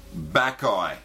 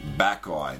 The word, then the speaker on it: Bacchae: Number one, Backeye, Pleasure Vision. Let me spell that for everybody. Backeye, B-A-C-C-H-A-E.